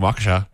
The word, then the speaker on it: Waukesha: We heard from Nicole from Waukesha, Wisconsin. Is that how you pronounce it? – Nice. Yeah. – Is that? – Yeah, from Waukesha.